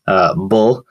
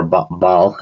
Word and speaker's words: Baal: And then the Canaanite fertility deity, Bull. Or Baal, I think is how you say it, who had rites of imitative magic, and then An for Osiris, which is the ancient Egyptian god of the underworld.